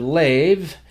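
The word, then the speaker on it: lev (לב): And the word heart there translates the Hebrew word lev, L-E-B, but there the bait lacks the dogesh, so it has a softer pronunciation, almost like a V sound, the lev.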